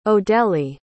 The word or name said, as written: odele